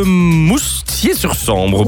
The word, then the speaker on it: Moustier-sur-Sambre: Et pour jouer avec nous aujourd'hui, on accueille Claudia de Moustier-sur-Sambre. Bonjour Claudia. – Bien, bonjour. – J'ai bien prononcé ou pas? – Oui, c'est très bien.